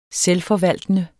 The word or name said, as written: selvforvaltende